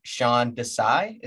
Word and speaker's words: Sean Desai: How do you feel about your new defensive coordinator, Sean Desai? Is that how it's pronounced? – Yeah, it's so hopes are very high.